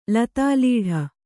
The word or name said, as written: ಲತಾಲೀಢ